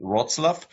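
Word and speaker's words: Wrocław: Is it Rocklaw? – The Polish call it Wroclaw. The German name, it was a German town, is Breslau.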